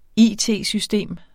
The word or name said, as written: it-system